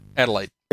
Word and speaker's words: Adelaide: Adelaide.